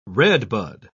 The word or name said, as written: redbud